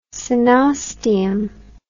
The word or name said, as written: coenosteum